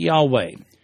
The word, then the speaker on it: Yahweh: And that's why some people today pronounce it Jehovah, while others pronounce it Yahweh. Technically speaking, however, there is no J sound in Hebrew. Those words actually begin with a hard Y consonant.